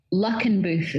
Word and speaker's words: Luckenbooth: So Luckenbooth is pronounced luck, Luckenbooth. – Okay. – So that's the way that we would pronounce it in Edinburgh.